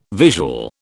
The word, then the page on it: visual: British English